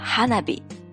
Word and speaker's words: hanabi: 花火呢，在日文的发音中是叫 hanabi，其实就是我们中国所说的烟花。